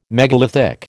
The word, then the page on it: megalithic: American English